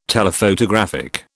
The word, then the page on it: Telephotographic: British English